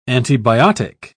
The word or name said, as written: antibiotic